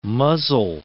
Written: muzzle

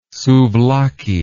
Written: souvlaki

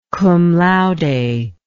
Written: cum laude

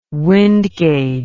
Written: wind gauge

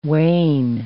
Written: wain

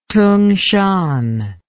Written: tungshan

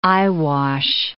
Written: eyewash

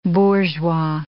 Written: bourgeois

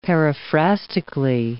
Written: paraphrastically

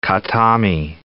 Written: Khatami

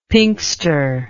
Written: pinxter

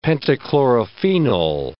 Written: pentachlorophenol

